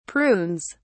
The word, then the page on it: prunes: British English